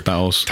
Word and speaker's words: Taos: So, we have on the small end, that's Taos. – Exactly. – Is that how you pronounce it, Taos? Or? – Taos, yes.